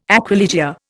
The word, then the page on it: aquilegia: Indian